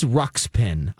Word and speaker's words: Ruxpin: It is Ruxpin. I am saying it wrong. – Yes, we know. We were just being too nice to correct you. Oh, I'm saying like skin, and it's pin.